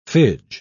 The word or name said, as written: fidge